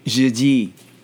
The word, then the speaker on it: jeudi: Or, as the French would say it, jeudi.